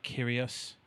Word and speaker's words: Kyrgios: But I am a bit nervous about Nick Kyrgios.